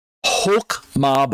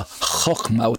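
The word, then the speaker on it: Chokmah (חכמה): but pronounced as Chokmah by some people, but Chokmah by others. I go with the Chokmah, with the, the guttural sound there.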